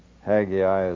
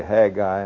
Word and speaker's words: Haggai: Incidentally, I ran across a note, I don't know where I got it, saying that Haggai is a three-syllable word. So some say Haggai and some say Haggai.